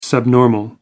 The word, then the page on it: subnormal: American English